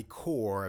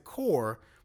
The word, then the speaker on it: Corp: Now, Core is also a word, C-O-R-P-S. You know, like the Army Corps of Engineers, but that's a completely different thing. Corp is an abbreviation of the word corporation, so it should be pronounced Corp. But for some reason, they pronounce it Core more than once in this episode, and I'm just like, I'm just losing my fucking mind?